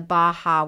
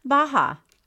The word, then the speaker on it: Baja: I thought you were tripping up on Baja because you wanted to pronounce the J.